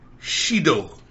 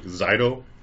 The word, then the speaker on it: Shido: Up next, Shido, featuring Moonbyul. – Glad you pronounced that, because I was like, Zido? – Yeah, I looked at the Korean name, Shido.